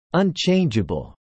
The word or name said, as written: unchangeable